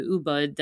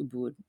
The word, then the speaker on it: Ubud: When you're in Bali, it's easier to get from Canggu to Ubud than if you're flying all the way from Sydney. I think I said that wrong. I think it's Ubud, by the way.